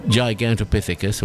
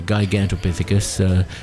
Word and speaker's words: Gigantopithecus: I've mentioned this before, and he says at one point, this creature, Gigantopithecus or Gigantopithecus, depending on your pronunciation, is not correctly named.